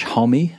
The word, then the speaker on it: Homme: Josh Homme, apparently it's a two-syllable last name, who knew?